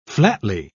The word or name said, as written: flatly